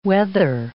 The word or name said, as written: whether